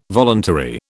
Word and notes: voluntary: British English

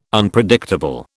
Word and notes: Unpredictable: British English

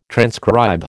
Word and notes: transcribe: American English

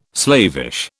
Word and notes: slavish: British English

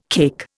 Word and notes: cake: Indian

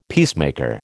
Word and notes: peacemaker: American English

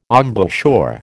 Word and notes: embouchure: American English

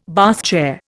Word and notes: Bath chair: Indian

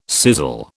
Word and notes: sizzle: British English

